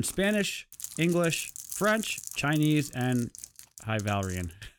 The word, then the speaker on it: High Valyrian: High Valyrian? – Yes. – Is that how you pronounce it? – Ah. – Yeah, that's the Game of Thrones.